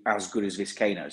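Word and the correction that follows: Vizcaino: I think you're pronouncing it wrong. Must be that English accent. It's Viscaino. Viscaino.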